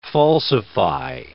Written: falsify

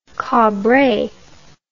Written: cabre

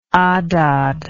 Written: Adad

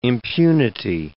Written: impunity

